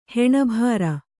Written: ಹೆಣಭಾರ

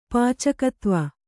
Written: ಪಾಚಕತ್ವ

